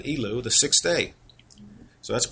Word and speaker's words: Elul: And that's how you say it in Hebrew, Elul, Elul.